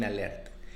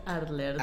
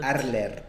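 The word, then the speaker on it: Arlert: Este, los protagonistas, pues ya los dijimos, es Eren, Eren Jacob, así como lo llaman ellos, Mikasa Ackerman y Armin Alert. Está bien padre porque en el caso de, Arlet, no es alert. – Arler. Arler.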